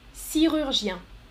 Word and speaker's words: chirurgien: En français, on dit, on prononce ch, le ch, ch, chi, chirurgien. Chirurgien.